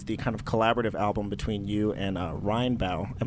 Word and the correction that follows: Rainbow: Am I pronouncing that correctly? Is it Rheinbau? – Oh, Rainbow. – Rainbow. My goodness.